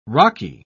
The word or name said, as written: rocky